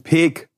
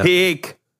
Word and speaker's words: Peek: Das heißt wo, ähm, Peek wo und explizit Kloppenburg. – Ja? Peek, ja. Peek. – Und da wird auch sehr viel Wert drauf gelegt in der Werbung, dass das so ausgesprochen wird.